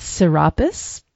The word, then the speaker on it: Serapis: And another thing that I read in 36 Faces was about the association of this Deccan with Serapis. Is that the right pronunciation? – Oh, yeah, So, and the idea that, you know, solar, but also connected with Osiris and the underworld.